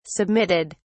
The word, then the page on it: submitted: British English